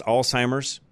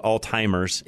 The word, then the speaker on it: Alzheimer's: So, and some of you probably also noticed that I try to pronounce Alzheimer's with the Z as much as I can, because it's not a T. You'll hear a lot of people, and I do my very best to make sure that I pronounce that correctly. Sort of a pet peeve of mine, I guess. I hear so many people call it Alzheimer's, and it's not.